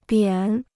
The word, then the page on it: 扁: biǎn